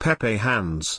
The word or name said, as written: pepehands